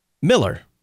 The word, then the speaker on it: Mueller: And I think I based it on the fact that I have a good friend, Pastor Herbert Miller, who pronounces M-U-E-L-L-E-R, Miller. Herb Miller. It's not M-I-L-L-E-R, it's M-U-E-L-L-E-R.